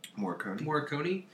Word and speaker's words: Morricone: Morricone has done several compositions for Tarantino's film, but it was never original.